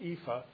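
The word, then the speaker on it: EAFE: And so they created the EFA, and that's how you pronounce it, EFA, Europe, Australia, and the Far East.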